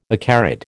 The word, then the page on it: acarid: American English